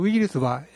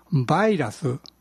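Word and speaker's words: virus: このウイルスは英語で何て言うでしょうか。綴りは V-I-R-U-S で、ですが発音はウイルスではありません。 V-I の I は E ではなく I と発音され、バイラスです。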